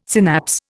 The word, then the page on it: synapse: Indian